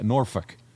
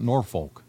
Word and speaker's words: Norfolk: I've saw on social media, that's how the locals say it, Norfolk, okay, not Norfolk, to watch the Horsemen get their asses kicked.